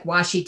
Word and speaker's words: washi: I call it washi. What's it called? – Are you talking about like washi tape? – Yes, that's it.